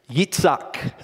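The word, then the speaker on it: Yitzhak (יצחק): Yitzhak is how you would say it in Hebrew, which means Isaac.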